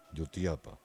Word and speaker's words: Jutiapa: Es así como se pronuncia, ¿verdad? Jutiapa. Para que... Jutiapa. Jutiapa. Ya.